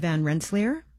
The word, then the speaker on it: Van Rensselaer: Schuyler was married to Catherine Van Rensselaer. Am I saying her name correctly? – Mm-hmm. – Yes, they were parents to 15 children, 8 who survived into adulthood.